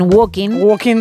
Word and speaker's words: walking: Os diría que no pronuncian la L, al igual que ocurre en Walking Dead.